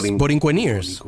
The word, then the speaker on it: Borinqueneers: Okay, I gotta pronounce this. Borinqueneers? Borinqueneers. – Ooh, that sounds good. – Like musketeers. Borinqueneers.